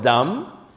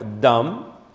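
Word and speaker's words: Dam (דם): ¿Cómo se dice sangre en hebreo? Dam. Dam es sangre en hebreo. Dam.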